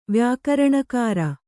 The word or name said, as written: ವ್ಯಾಕರಣಕಾರ